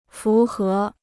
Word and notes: 符合: fú hé

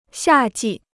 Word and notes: 夏季: xià jì

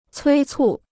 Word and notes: 催促: cuī cù

